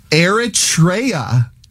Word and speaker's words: Eritrea: It's called Eritrea and that is the correct pronunciation.